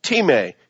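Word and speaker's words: time (τιμή): It's spelled like time, T-I-M-E, but it's pronounced time.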